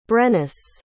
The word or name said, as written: brennus